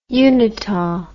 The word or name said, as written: unita